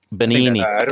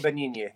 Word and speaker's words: Benigni: Am I saying that right? I know he's one of your favorites. Roberto Benigni? – Benigni. – Roberto Benigni. Yes, that's Roberto Benigni.